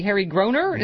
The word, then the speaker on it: Groener: and then Broadway star and Tony nominee Harry Groener.